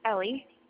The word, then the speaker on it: Ellie: Ellie. – Ellie, I'm sorry, could you spell it for me? – E-L-L-I-E.